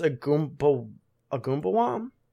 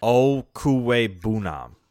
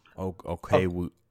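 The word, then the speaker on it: Okwuegbunam: It's like Albert, I think it's Agumbawam? No, that's absolutely wrong. – Nah, so it's pronounced Okuwebunam. – Okuwebunam.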